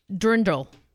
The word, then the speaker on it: dirndl: She's wearing her dirndl.